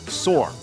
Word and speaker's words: saw: And you will hear him pronounce the word S-A-W as S-A-W-R, sore.